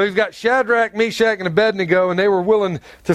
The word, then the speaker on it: Abednego: We're going to see Shadrach, Meshach, and Abednego. Now, Abednego, because some people go, how do you say that? It's A-bed-N-E-go. Abednego. Abednego.